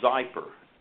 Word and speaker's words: Xypper: it's called Xypper and uh, it's it's spelled X Y double P E R, Xypper.